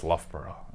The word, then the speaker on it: Loughborough: I said, so you went to Lowbrow University? He did not appreciate that at all. He was getting yourself over worldwide, Boat? – He went, it's Loughborough.